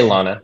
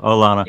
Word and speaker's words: Llana: I just say Llana. Jose Llana. – Llana.